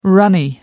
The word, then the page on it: runny: American English